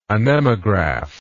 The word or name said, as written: anemographically